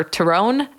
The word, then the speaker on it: Tyrone: It's Cookstown of County Tyrone. Or Tyrone, however you would like to pronounce it, but it's literally spelled Tyrone, like the name.